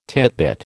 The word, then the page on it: titbit: American English